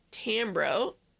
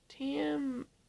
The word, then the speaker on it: Tambor: Jeffrey Tambro played the mayor. Did I say his name wrong? – Tambro? – Tamboro. Tambor. Tambor? – Tamber.